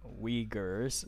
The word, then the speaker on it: Uyghurs: So as I was saying, you're also potentially complicit in the mass incarceration of Muslim Uyghurs.